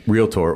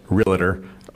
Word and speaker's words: realtor: And that's why when people say, you know, I'm a realtor or realtor, some people, they add that extra syllable in there.